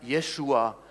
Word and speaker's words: Jeshua: Im Hebräischen wird er Jeshua ausgesprochen und heißt von der Bedeutung her, dass Je als Vorsilbe ist eine Kurzform von Yahweh.